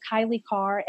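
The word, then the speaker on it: Kailei Carr: I'm Kylie Carr everywhere, and that's spelled KAILEICARR.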